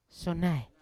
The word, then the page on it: syonai: Yawa